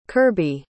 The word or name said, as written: curby